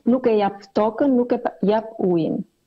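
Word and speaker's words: Nuk e japim tokën, nuk e japim ujin: Πώς προφέρεται η αλβανική μετάφραση? – Στα αλβανικά το είπαμε σε μια εκδοχή δεν δίνουμε τη γη, δεν δίνουμε το νερό, νούκε γιαπ τόκεν, νούκε γιαπ ουίν.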